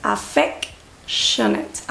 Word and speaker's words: affectionate: Y cuidado con la pronunciación, es affectionate, affectionate, affectionate.